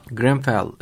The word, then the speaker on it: Grenfell: Londra Emniyeti Müdürlüğü'nden Stuart Cundy Greenfield, doğru telaffuz ediyorum değil mi? – Grenfell. – Grenfell Tower yangında en az 58 kişinin kayıp olduğunu ve bu kişilerin yaşamını yitirdiğini de varsayıldığını söylemiş.